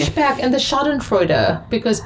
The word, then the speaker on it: Schadenfreude: Schadenfreude. – Schadenfreude.